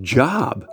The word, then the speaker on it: Job: You know, Christians are all celebrating when they come to Job in the Bible because they know that word, J-O-B, is not pronounced Job.